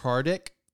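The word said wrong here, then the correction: Hradek: I don't know who they are, but they're for the NHL. What? – Radic. – Okay. – Oh, the H is silent.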